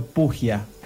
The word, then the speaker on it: Pujia: Leandro Pugia. Ah, el acento es, complicado, vos sabés, así que queda como libre. Como quieras pronunciarlo, va a estar bien porque es de esos apellidos que los escribieron mal cuando, cuando entraron a Argentina desde Italia.